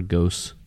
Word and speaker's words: Gose: All right, so Gose is spelled G-O-S-E, by the way. It's not Gose or Gose. It's Gose.